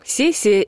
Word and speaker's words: сессия: Однако в орфоэпическом словаре русского языка Ирины Резниченко «сессия» входит в число слов с неустойчивым произношением, то есть в начале слова допускается и твердый, и мягкий звук «с». При этом мягкое произношение отмечается как предпочтительное.